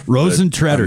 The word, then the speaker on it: Rosentreter: Rosentreter.